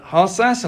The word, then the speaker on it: Harsas: And Amanda Harsas, and I hope I pronounced that right because it's a Greek name. Welcome to you. – Thank you. Yes, you did pronounce it correctly.